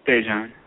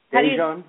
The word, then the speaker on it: Dajon: Dejan. – I'm sorry. You got to speak up a little bit more so we can help you out. – Dejan. – Dejan. How do you spell that, please? – D-A-J-O-N.